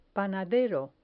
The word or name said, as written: panadero